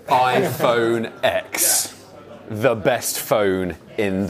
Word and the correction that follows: iPhone X: It's pronounced 10. It's 10. – iPhone 10.